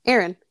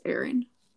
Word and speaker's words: Ehren: I didn't know how to pronounce Aaron until just this moment. I had no idea. It's spelled E-H-R-E-N. – Aaron.